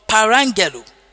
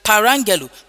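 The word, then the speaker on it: Parangelo (παραγγέλλω): But the word command there is the word parangelo. Hallelujah. Parangelo. Parangelo is spelled P-A-R-A-G-G-E-L-O. P-A-R-A-G-G-E-L-O.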